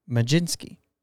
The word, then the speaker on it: Mlodzinski: Number 11, Carmen Majinski. And I had to look up how to say his name. M-L-O-D-Z-I-N-S-K-I. Majinski.